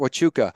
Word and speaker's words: Huachuca: Fort Huachuca.